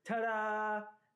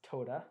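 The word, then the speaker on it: toda (תודה): You know, a common way the word thanksgiving is translated in the Bible is the Hebrew word tada. Actually, it's pronounced toda, but it's fun to say tada.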